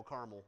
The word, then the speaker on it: Carmel: How do you pronounce it? Carmel? There's no A in the middle. Carmel in this case.